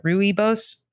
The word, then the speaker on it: rooibos: R-O-O-I-B-O-S. – R-O-I-B-O-S? – Yeah. R-O-O-I-B-O-S. – All right. I don't know. I'm going to go with your pronunciation because that makes no sense to me.